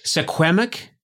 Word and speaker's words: Suquamish: I want to make sure I say it right. Suquamish? – Or that's pretty good. No, that you did really well there.